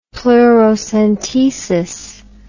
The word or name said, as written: pleurocentesis